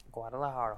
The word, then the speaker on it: Guadalajara: Guadalajara, hometown.